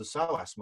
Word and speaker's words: psoas: And that's because the psoas muscle is connected to the lumbar spine. Look it up. It's got a silent P. P-S-O-A-S. Psoas muscle.